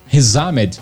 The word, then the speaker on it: Riz Ahmed: É o Riz Ahmed Rizamed, para juntar tudo assim na pronúncia mais é, do inglês,